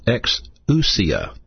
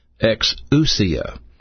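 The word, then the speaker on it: exousia (ἐξουσία): This word power is pronounced exousia. Exousia.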